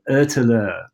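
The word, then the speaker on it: Ötillö: I always butcher the pronunciation, but I believe the Swedish pronounce it something along the lines of Ötele, which means island to island.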